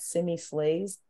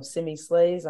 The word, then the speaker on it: SimiSleighs: You can find me at Simi Slays. So remember, mm-hmm. the Slays is spelt differently than you typically might expect. So my handle is SimiSleighs. So Simi Slays, I'm on Instagram, Facebook, Twitter, LinkedIn.